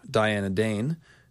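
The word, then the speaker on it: Dyanna Dayne: Maekar, meanwhile, has a wife, Diana Dane, whose name rhymes with lion.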